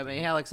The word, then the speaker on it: Mihelich: Well, Mihelich's how we pronounce it out here in the Midwest. They say Mihelich. Some people say Mihelich. It is really all over the place.